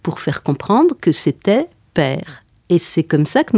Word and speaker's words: Paër: Tandis que là, on en vient à cette dissociation, Paër, ce Ferdinand qu'on devrait appeler père.